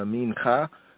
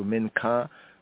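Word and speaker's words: minka (מנחה): And just a little bit more right here on the mincha, Hebraically from the Hebrew, mincha. In this form here, minchat. Others say the minka. My hear I and I say the minka, as it's pronounced in modern Hebrew, minka.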